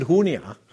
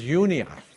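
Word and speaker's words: Junia: Okay, in Hebrew, unlike Spanish, the J is like an H. In Hebrew, the J is like a Y. Okay, so this isn't Hunia. This is Unia. Okay, Andronicus and Unia, my countrymen and my fellow prisoners, are of note among the apostles who also were in Christ before me.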